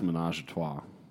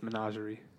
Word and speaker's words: Menagerie: I think it's pronounced The Glass Menage a Trois. – No, it's Menagerie.